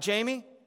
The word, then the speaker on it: Jamey: And if you're in this room and you have a weird name, like Jamie, and your mom didn't spell it J-A-M-I-E, but she wanted to spell it J-A-M-E-Y because there's some guy on her soap opera that apparently she wanted me to name after. If you were wondering. To constantly have to say, hey, it's J-A-M-E-Y.